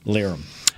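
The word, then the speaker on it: Lirim: It's L I R I M. Yeah. But Lyram.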